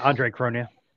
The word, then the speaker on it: Andre Cronje: More recently, we saw an exchange on Phantom, Solidly, was launched, and I believe it was an Andre Cronje. I don't know if I'm saying that right. – Yeah, Andre Cronje.